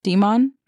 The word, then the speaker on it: daemon: Daemon? Demon? – I think they say demon, but it's spelled Daemon.